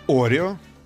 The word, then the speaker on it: Орео: Орео, хорошо, хорошо. Именно так, да, вы считаете? – Ну, у них всегда ударение на первый слог. – Орео.